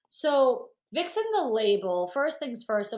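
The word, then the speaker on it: Vicsen: Yeah, no, it's Vixen, like how we've always heard like what a Vixen is. So it's that, but it's spelled Vic like Victoria.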